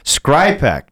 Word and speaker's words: Skrzypek: Skrypek, and it's spelled Skrzypek.